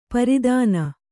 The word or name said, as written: ಪ್ರತನ